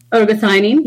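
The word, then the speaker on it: ergothioneine: Am I pronouncing that right? Ergothionine? – Ergothionine, yes.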